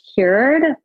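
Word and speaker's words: Kyoord: I think it's pronounced cured, but it's K-Y-O-O-R-D.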